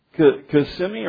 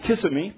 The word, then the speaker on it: Kissimmee: Man, we went down to Kissimmee, or what is it? I called Kissimmee because it's spelled that way. Don't spell Kissimmee and then tell me it's Kissimmee.